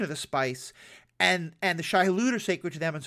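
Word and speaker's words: Shai-Hulud: But one of the things that we're starting to learn is that the spice is connected with the worms, the Shai Hadat. I'm probably pronouncing that wrong. – Shai Hulud, thank you.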